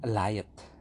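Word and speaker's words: Alioth: namanya Aliot kalau nggak salah Aliot bacanya Aliot kalau di serialnya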